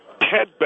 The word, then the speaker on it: Ted: I'm Ted Bell, okay? Ted Bell. T-E-D-B-E-L-L.